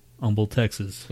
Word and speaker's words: Humble: Like humble, like in Houston. Humble, Texas. – Yeah. – But spelt with an H. And the H is not silent. Humble.